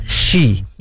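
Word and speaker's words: shi (四): Nasce questa maledizione del 4, questa tetrafobia, da questo numero che si pronuncia shi in giapponese e ha lo stesso suono della parola morte.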